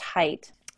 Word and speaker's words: Haidt: think it's pronounced height.